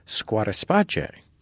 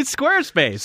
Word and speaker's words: Squarespace: This week, it's also supported by our friends at Squarespace, which they pronounce, interestingly, Squatterspace. – Oh, I don't think they do. They probably want us just to say Squarespace. – So we shouldn't just say Squatterspace. – No, we shouldn't.